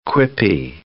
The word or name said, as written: quippy